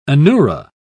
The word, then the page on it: anura: British English